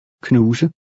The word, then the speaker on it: knuse: The S has a Z sound. – Knuse.